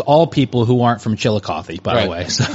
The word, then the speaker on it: Chillicothe: It's Chillicothe. – It ends with a T-H-E. Right? – Yeah. Chillicothe. – Chillicothe.